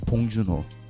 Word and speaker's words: Bong Joon-ho: His name is Bong Joon-ho. I'm going to say it more Korean. Bong Joon-ho. That's how you say it.